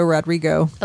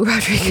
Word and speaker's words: Rodrigo: Let me say her name right. album Sour. I keep wanting to add an O to the end of both. Olivia Rodrigo. Olivia Rodrigo.